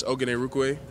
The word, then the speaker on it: Ogunerukwe: Yes, it's Ogunerukwe. It means God has done so much for me.